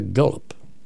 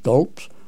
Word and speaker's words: Guelph: If you plan to be anywhere near Gulp, Ontario, that's spelled G-U-E-L-P-H, Gulp, Ontario, in the future, make a point of stopping in at the Hammond Museum, founded by one of QCWA's greatest members.